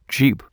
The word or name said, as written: jeep